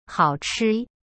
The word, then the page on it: 好吃: hao-chi